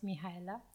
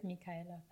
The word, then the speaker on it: Mihaela: Ton prénom, ça se prononce euh, Mihaela Non, ça se prononce Mihaela.